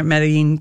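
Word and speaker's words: Medellín: Medellin, which is, I think how I'm pronouncing it, Medellin, Colombia.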